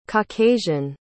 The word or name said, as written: caucasian